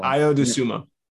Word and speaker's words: Ayo Dosunmu: Ayo Dosunmu. Apologies if I butchered that one. – Ayo Dosunmu.